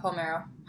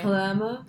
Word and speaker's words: Palermo: The, Olivia Palmero. How do you say? – Palermo.